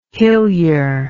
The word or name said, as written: hillyer